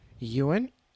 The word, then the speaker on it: Ewan: I think you say Ewan. – Ewan? Ewan. – Ewan Ewan. – McGregor. Like a Y.